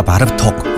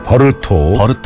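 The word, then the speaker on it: Bartók: 바르톡. 헝가리 작곡가인데요. – 바르톡. – 우리나라에서 오랫동안 바르톡이라고 불렸지만 헝가리 말로는 버르톡이라고 불러야 맞습니다. – 버르톡. – 예. 스펠링은 A라고 돼 있지만요. 이 버르톡이라고 불러야 되고요.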